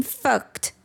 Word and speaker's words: fucked: Isso, você pode contextualizar como I'm fucked, eu tô fudido. Lembrando que é no passado, tá, gente? Atenção à pronúncia, fucked, no passado.